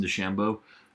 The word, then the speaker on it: DeChambeau: But I'm really definitely interested in Bryson DeChambeau.